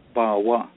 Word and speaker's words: pawwaw: And that's because powwow is an old Algonquin word, but it's spelled P-A-W-W-A-W, Bawa, which is how we pronounce powwow traditionally, Bawa. We say, Ki Bawa Hiben. We don't say, we're going to powwow. Because we're still saying it in the old Algonquin way, Bawa.